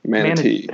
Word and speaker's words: manatee: You pronounce it with a D. Manatee. You got to hit that T.